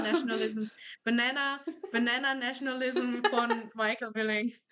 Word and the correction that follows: Billig: Ist es überhaupt Michael Billing? Ja, Michael Billig, nicht Billing.